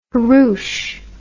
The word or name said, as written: hurroosh